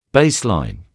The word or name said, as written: Baseline